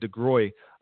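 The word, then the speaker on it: DeGruy: Or Dr. Joy DeGraw, who talks about, or I don't know if I said her name right, DeGroy, who has the post-traumatic slave syndrome research.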